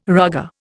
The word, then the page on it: rugger: Indian